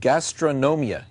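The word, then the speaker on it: Gastronomía: I think I said that right. Gastronomia. Yes, it did.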